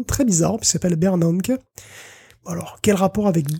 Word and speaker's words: Bernanke: J'ai testé parce que depuis que je prononce mal les choses, on m'a recommandé d'utiliser Google Traduction et d'utiliser le petit haut-parleur. C'est Bernanke. – Bernanke. – Voilà, disons sachons-le, Bernanke.